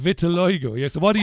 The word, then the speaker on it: vitiligo: It's vitiligo.